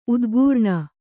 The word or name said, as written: ಉದ್ಗರಣೆ